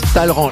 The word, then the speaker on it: Talleyrand: Ça s'écrit Talleyrand, mais ça se prononce Talleyrand.